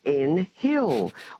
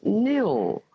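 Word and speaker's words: Nhill: And we often get called N-Hill. Well, it's not. The H is silent, and it's N-H-I-L-L, of course, and it's pronounced Nil.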